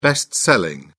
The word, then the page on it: best-selling: British English